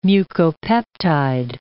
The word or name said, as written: mucopeptide